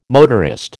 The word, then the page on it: motorist: American English